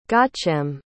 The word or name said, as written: gaucelm